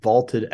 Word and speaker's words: Vaulted: That is on the Vaulted app, VLTED, pronounced vaulted, a new sports prediction app that turns your opinions into facts.